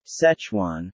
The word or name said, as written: Szechuan